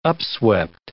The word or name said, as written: upswept